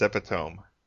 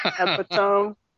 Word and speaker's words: epitome: When I was growing up, I thought epitome was pronounced epitome. – Epitome. – Look at how it's spelled.